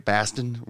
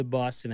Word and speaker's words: Boston: Baston, whatever. Anyway, that. – No, hold on. We got to correct something real quick. It's not Baston. It's Boston. Like, it's if you want to do the Boston accent right, it's B-A-W, like Boston, not Boston.